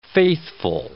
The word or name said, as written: faithful